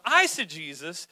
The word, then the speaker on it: eisegesis: Now, eisegesis, a good way to remember it is like I, even though it's E-I is the way it's spelled, but